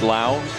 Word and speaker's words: Lowe: There are people in this ballpark that don't know that L-O-W-E is pronounced Lau for Brandon's name, so they're going Urban Lowboy.